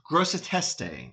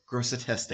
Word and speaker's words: Grosseteste: Grosseteste is the way I should have pronounced it had to look it up mm-hmm. real quick. – Grosseteste.